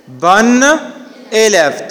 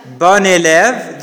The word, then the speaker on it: bonne élève: Do you feel the same pronunciation of the last and the second one? Is it the same or not? Yeah, it's the same pronunciation. Bon élève, masculine and feminine.